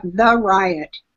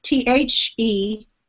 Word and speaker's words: Theriot: It's spelled like The Riot. Theriot.